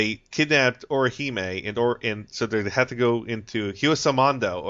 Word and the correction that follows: Hueco Mundo: or I can't pronounce it, Pablo? – Hueco Mundo. Okay.